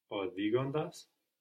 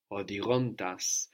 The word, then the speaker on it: οδηγώντας: Οδηγώντας. – Οδηγώντας. So here we have the accent on the όντας because we have the accent on the ending of οδηγώ.